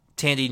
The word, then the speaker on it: Thandiwe: We have Molly and Ginger, voiced by Bella Ramsey as Molly, and Thandiwe Newton as Ginger? – Tandy Newton. – Tandy. I've never heard the name pronounced before, out loud, so I didn't know how to say it.